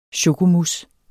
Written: chokomousse

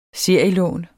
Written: serielån

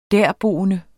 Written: derboende

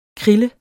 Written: KRILLE

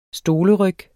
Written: stoleryg

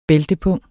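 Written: bæltepung

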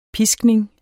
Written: piskning

